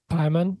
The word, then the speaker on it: Paimon: And so Hunter did a deep dive of this film, and there's a lot of, is it pronounced Paimon? – Paimon.